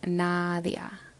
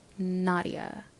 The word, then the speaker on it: Nadia: It's Nadia. Nadia.